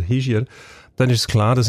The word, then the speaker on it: Hischier: Ähm, richtig muss man, glaube ich, sagen Hischier, weil er ein Oberwalliser okay, ist.